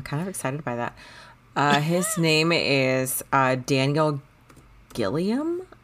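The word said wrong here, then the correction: Gillies: I think, is how you pronounce Gillis? – It. – Gillis. – Gillis. Yeah, sorry.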